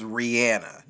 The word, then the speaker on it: Rihanna: Because that's how she pronounces her name. Look it up. – But you know how some people from like certain areas. – It's been a very hotly debated topic. Look it up. Every time she said, she even corrects you without correcting you, but every time she says her name, she says Rihanna, not Rihanna.